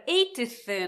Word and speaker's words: eighth: あのこれ eighth なんですけれど、はっきり言うと eighth。だけど、もうほぼ t 発音しなくて大丈夫かもしれません。